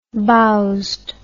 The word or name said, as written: bowzed